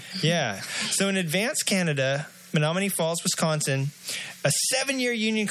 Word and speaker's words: Menominee: Headlines, you almost said it right. Menominee Falls, Wisconsin. – Oh, Menominee. – Menominee. Is that where that song came from? Mm-hmm. So Menominee Falls, and someone from Wisconsin is going to call in and throw a beer at me straight through the emails and hit me because of I couldn't pronounce Menominee. – That's like 90% of our listeners. – Menominee. You're right.